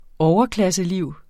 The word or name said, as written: overklasseliv